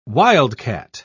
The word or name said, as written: wildcat